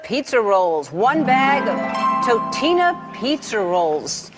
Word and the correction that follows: Totino's: Totino's, bitch.